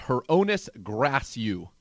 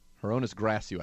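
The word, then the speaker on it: Hroniss Grasu: And from talking to some of the offensive line coaches around this team, it's Hronis Grasu. Hronis Grasu, I've been told the pronunciation is there.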